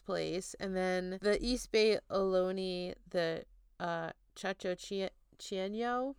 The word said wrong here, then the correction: Chochenyo: sorry, I'm so bad at pronouncing things. Chochenyo, they convey an image of the mountains as a very powerful place that could mysteriously hide things.